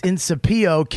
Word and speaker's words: Incipio: I-N-C-I-P-I-O, no dot. It's in Sapio case.